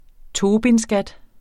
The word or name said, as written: tobinskat